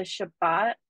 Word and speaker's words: Shabbat: So I think when you say the ways that you are adopting the Shabbat, am I saying that right? Yeah. Yeah, fuck yeah.